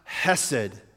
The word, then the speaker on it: hesed: It's spelled C-H-E-C-D, but it's actually pronounced hesed, and it is a My wife loves this word, hesed.